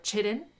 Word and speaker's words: chitin: Their cell walls are made of chitin. Or chitin, if you read it phonetically.